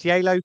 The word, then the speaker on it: Geilo: Did I pronounce Geilo correctly? – You did, yes, that's it. Although I did hear some Norwegians calling it Geilo as well there.